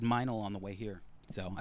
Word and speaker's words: Meinl: Yeah, so Meinl, okay.